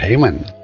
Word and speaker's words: Hayman: En, inglés se pronuncia así. En español sería Heyman pero eh, en inglés Heyman